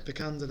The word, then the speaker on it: Vikander: I think it's Alicia Vikander. – Vikander, there we go, let's go with that.